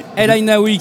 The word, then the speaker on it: El Aïnaoui: El Aïnaoui, comme ça qu'on prononce. – Tout à fait, El Aïnaoui